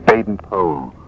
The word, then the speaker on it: Baden-Powell: And you won't hate me for this, but the family are quite particular about it. It's pronounced Baden-Powell.